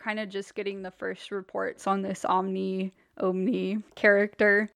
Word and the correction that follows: Omicron: Omicron is how you say that.